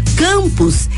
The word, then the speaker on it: campus: Campus é uma palavra paroxítona, porque tem a penúltima sílaba tônica. A sílaba can é pronunciada mais forte e campus termina em us.